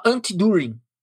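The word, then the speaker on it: Anti-Dühring: Chama Anti-During. Né? Anti-During numa pronúncia inglês é Antidurin, é, numa pronúncia, no máximo que eu consigo aí, de uma pronúncia alemã.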